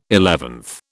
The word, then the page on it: eleventh: British English